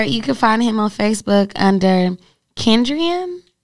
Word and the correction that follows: Kenadrian: Yeah, Canadrian. – Canadrian. I don't know. He spells it K-E-N-A-D-R-I-A-N Bowler.